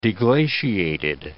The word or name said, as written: deglaciated